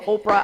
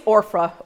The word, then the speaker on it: Orpah: I kept calling her Oprah. I said, guys, I'm about to call her Oprah. But her name is Orpah.